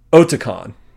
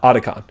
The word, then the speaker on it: Otakon: It is Otakon, not Otakon, which is how I've always said it because of Metal Gear Solid.